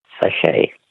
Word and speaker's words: sachet: Sachet. – A sachet. S-A-C-H-E-T.